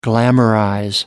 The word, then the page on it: glamorize: American English